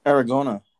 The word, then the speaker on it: Aragona: It was funny because David Aragonda, who does the – that's not his pronounced name, right? Pronounce name, right? – Aragona, right? – Yeah.